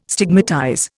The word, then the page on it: stigmatize: Indian